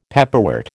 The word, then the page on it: pepperwort: American English